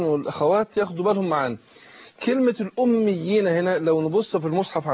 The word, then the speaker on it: الأميين: يعني لما مكنتش من الياء لو أنا أقول أميين كده الأمي، يبقى نطقت ياءين، بس أنا شددت الأولانية عشان إيه أمكن للتانية، يبقى أنا إيه ده اسمه تمكين الأميين.